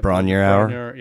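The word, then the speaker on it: Bron-Yr-Aur: Bronier Hour.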